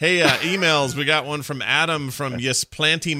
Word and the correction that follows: Ypsilanti: Michigan, or how do you say it? YP. – Oh, Ypsilanti. – Ypsilanti. Is that how you say it? Okay. Wow, you are Ypsilanti. not even close.